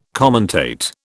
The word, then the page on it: commentate: British English